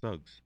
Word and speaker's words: Suggs: That's how you say it, right? It's not Suggs? – Suggs.